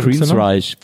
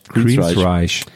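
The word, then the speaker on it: Queensrÿche: Dann äh, Queens Rich, wie spricht man die aus? Queens, die mit dem Punkten über Queensreich. Queensreich.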